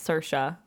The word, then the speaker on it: Saoirse: I said her name wrong last week, even though I went on a thing about... Yes. – It's okay. – It's Saoirse. No, it's not. It's Saoirse.